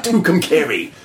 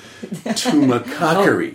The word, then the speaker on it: Tumacacori: And so it wasn't long before then that we moved even further south of Tucson to a town called Tumacacori. Not Tucumcari. Tumacacori. – Oh, if people mispronounce it.